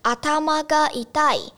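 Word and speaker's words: 頭が痛い: 比较难去发音，而且也比较没有那么口语，所以他们比较多说第二个用法，叫做阿他妈嘎一代，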